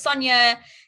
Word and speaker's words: Sonya: my name is Sonya, it's spelled with a Y.